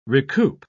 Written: recoup

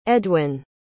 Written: edwyn

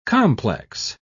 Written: complex

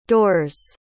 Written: doors

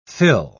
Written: thill

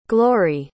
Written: glorie

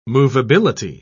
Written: movability

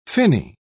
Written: finny

